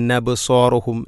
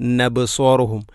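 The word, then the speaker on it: അബ്സാറുഹും (أبصارهم): നൂനെക്കൊണ്ട് ഇങ്ങനെ ഖാശിഅതൻ അബ്സാറുഹും നബ്സാറുഹും, ഈ ഒരു വായന കേൾക്കാവുന്നതാണ്, അത് ശരിയല്ല.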